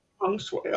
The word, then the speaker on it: feng shui: Feng shui, okay.